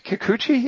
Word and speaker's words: Kikuchi: Did I say her name right? did I get it right? Kikuchi? – Yeah, yeah, right.